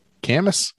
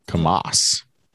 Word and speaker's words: Kamas: Camas. – K-A-M-A-S. – Yeah. – Camas. Camas. – Yeah, it's Cam. It's Utah. They're not going to put emphasis on that last syllable.